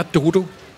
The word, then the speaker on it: Dodo: Det var en sjov historie, da du besøgte os sidst, Dodo. – Ja. – Og hvor vi lærte, at det er Dodo. – Ja, det er faktisk Dodo. – Og det er faktisk uden den engelske udtale.